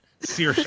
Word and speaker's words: Saoirse: Saoirse. Her name is Saoirse Ronan.